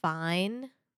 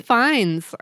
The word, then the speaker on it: Fiennes: And his last name is Fine. – Mm-hmm. – But spelled with like all kinds of I thought it was Fines.